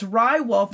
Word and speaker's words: Ri Wolf: If you're listening now, it's Rye Wolf, and it's spelled R-I Wolf, W-O-L-F.